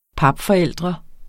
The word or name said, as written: papforældre